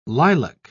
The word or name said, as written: lilac